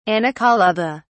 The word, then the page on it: anacolutha: British English